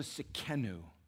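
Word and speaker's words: Tsidkenu: And it is into this setting of chaos and confusion and wickedness and wrongness that Jehovah, our righteousness, Jehovah Sekenu is how you say his name in the Hebrew.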